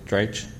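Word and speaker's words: Dratch: TV celebrities, Ryan Adams, Shannon Shore, of course, SNL's Rachel Dreich. Did I say that right? – Yeah, you did.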